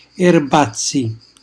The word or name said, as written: erbazzi